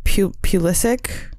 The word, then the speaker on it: Pulisic: Pulisic? – Yes.